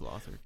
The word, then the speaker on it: Lothric: I think it's Lothric. Sorry, I keep pronouncing that wrong. – It's Lothric.